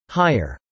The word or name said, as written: hire